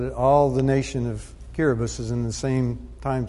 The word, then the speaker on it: Kiribati: And it's another one of these linguistic things, but Kiribati is spelled Kiribati. And just like I before E, except after C, the T-I in a sentence is pronounced as an S some of the time.